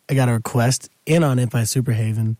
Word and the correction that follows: Superheaven: Superheaven? I thought it was Superhaven. Superheaven. Wow, I've been saying that wrong for years.